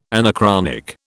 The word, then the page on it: anachronic: British English